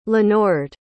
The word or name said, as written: lenord